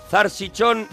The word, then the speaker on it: salchichón: Sí al salchichón, más si es a la pimienta. – Dice Salvador, zarchichón de toda la vida. En Granada se dice así también.